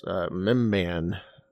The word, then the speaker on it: Mimban: And Rural Farm Boy in our chat says yes, Mimban is the pronunciation of that.